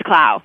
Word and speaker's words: Clow: Did I say your last name right? – That's great. It's Clough.